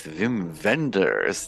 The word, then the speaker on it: Wim Wenders: Welcome everyone to the Next Best Picture podcast, where we are talking with Wim Wenders, the legendary director of the new film Anselm.